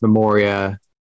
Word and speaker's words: Memoria: I've heard it pronounced Memoria by, I would think, people who would know.